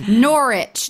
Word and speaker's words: Norwich: For example, Norwich. Norwich. Now there's a W in there, but they don't use that. So now I'm going to need y'all's help. We're going to Manchester, Leeds, Birmingham, Norwich, Glasgow, which I'm really excited about.